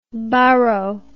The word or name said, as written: baro